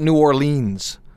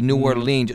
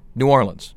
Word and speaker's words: New Orleans: I called it New Orleans. – No. – And people would stop me and they'd say, no, stop pronouncing it E, New Orleans. Just run it all together. New Orleans.